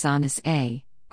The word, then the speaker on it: San Jose: San Francisco and Oakland, Santa Clara, San Jose, which is pronounced Sanus A, are large, influential cities.